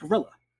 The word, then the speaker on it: guerrilla: So many liberation fighters around the world call themselves guerrillas, but in English, that's pronounced gorilla.